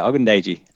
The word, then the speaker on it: Adetokunbo Ogundeji: Adetokunbo Ogundeji. – I think I can compete with that. I think that's right, yeah. That's great. Yeah, Ogundeji, Edge from Notre Dame.